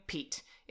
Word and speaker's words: Peet: My friend's 5-year-old daughter named him, calling him Pete. It's spelled P-E-E-T. P-E-T. P-E-E-T. Good Lord. It's called... His name's Pete. Okay, people? And it's spelled P-E-E-T because that's how a bird would spell it.